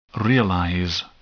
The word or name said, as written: realize